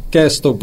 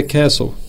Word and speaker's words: castle: Por exemplo, uma palavra que as pessoas pronunciam muito errada é a palavra castelo, né? Que em inglês as pessoas muitas vezes pronunciam castle, porque tem um T lá, mas esse T muda, é o silent T, né? No caso. Mas a pronúncia certa é castle. Apesar de o T estar na palavra, o T não é pronunciado, né?